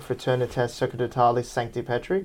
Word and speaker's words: Fraternitas Sacerdotalis Sancti Petri: But so the Priestly Fraternity of St. Peter was then formed, which is the fraternity, in Latin, how do you pronounce it? Fraternitas Sacerdotalis Sancti Petri? – That's right. – Yeah, that's right, yes.